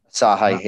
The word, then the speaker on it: Asahi: Because obviously, we mentioned before that Asahi, have I said that right? Bought Fuller's out, didn't they, some time ago? – Asahi, yeah.